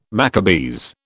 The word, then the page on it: Maccabees: British English